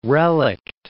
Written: relict

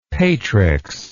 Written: patrix

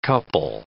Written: couple

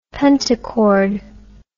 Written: pentachord

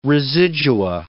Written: residua

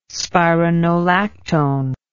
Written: spironolactone

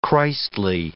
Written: Christly

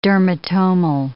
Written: dermatomal

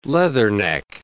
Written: leatherneck

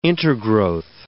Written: intergrowth

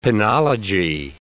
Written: penology

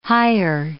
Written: hire

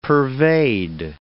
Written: pervade

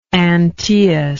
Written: Antaeus

